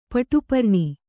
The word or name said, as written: ಪಟುಪರ್ಣಿ